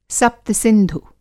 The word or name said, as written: सप्त-सिंधु